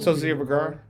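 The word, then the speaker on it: Sofía Vergara: How you pronounce it? – Sophia Vergara.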